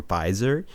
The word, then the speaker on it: Pfizer: The two of the vaccines that are either approved or about to be approved, the one from Pfizer, mm-hmm. – Which is spelled with a P in case we're Googling it.